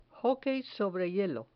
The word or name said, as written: hockey sobre hielo